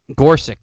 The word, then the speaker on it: Gorsuch: like Chief Justice Roberts and Garush or whatever his fucking name is, because Gorsuch, thank you.